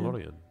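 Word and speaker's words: Olórin: It's Olorian? – Olorian? Olorian, yeah.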